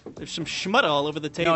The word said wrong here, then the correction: schmutz: No, you're thinking of schmutz.